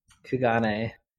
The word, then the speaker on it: Kugane: Kugane, am I saying that right? – Kugane.